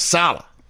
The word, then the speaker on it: Saleh: Wait, is that how you pronounced? I thought it was Saleh. – Saleh. It is. It's Saleh.